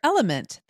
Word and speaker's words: LMNT: Sarah, we're welcoming our sponsor, Element. That's spelled L-M-N-T,